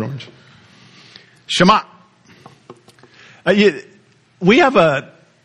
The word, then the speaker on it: Shema: Shema, close your throat right there. Shut it off. Shema. That's how you say it.